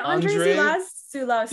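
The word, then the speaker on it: Andrzej Żuławski: Is it Andre Zulawski? – Yeah.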